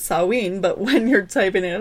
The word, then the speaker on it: Samhain: The festival of Samhain, which is weird because I'm pretty sure that's how you pronounce it, but it's spelled Samhain. Samhain is how they say to pronounce it.